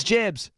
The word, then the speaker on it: JB's: So we're just going to spell it JB'S, Jibs? – It's Jibs. – Jibs. – JB, Jibs.